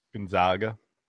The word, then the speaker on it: Gonzaga: Gonzaga. – Yeah. It's Gonzaga. No, it's like the Zags. Gonzaga.